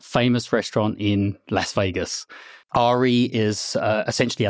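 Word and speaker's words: Aerea: It's a really cool palindrome, A-E-R-E-A. I pronounce it Ari. You can pronounce it however you would like. – Aria is what I keep going with. – And Aria is fine.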